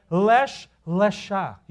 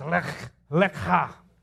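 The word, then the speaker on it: lech, lecha (לך לך): You know how you pronounce this word? Lach, lach lecha. I'm not kidding, okay? If you Google it and you have to go to YouTube and listen to how they pronounce it, it's pronounced as lech, lecha.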